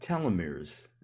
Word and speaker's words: telomeres: We've talked about telomeres, and I think I'm pronouncing them right. I think we want long telomeres, or do we want short telomeres? – Yeah, you got it.